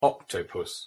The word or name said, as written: octopus